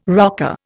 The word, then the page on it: rocker: Indian